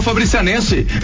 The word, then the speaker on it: Fabricianense: Mudou, já veio de Malicuia, já é um, como é que fala? Coronel Fabriciense? – Coronel Fabricianense. – É. – Já é Fabricianense.